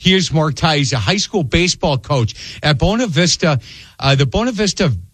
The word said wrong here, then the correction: Bonita Vista: Barons. Am I saying that correctly? – It's actually Bonita. – Bonita Vista Barons.